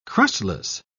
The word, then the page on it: crustless: British English